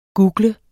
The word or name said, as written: google